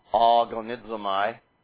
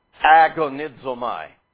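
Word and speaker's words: agonizomai (ἀγωνίζομαι): One, if we anglicized it, the first letter of that word being an alpha, pronounced ah, agonizomai.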